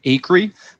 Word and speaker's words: Acree: You can find me at Luke Acree. So last name is spelled A-C-R-E-E. So Acre with an extra E.